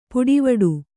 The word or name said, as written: ಪುಡಿವಡು